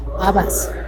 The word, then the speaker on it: habas: Habas. – Habas. – Habas con queso. – That's with the H, silent H.